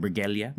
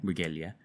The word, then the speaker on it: Briguela: How do you say how do you say their last name? Briguela. Briguela. – Briguela. – Au, Briguela. Briguela.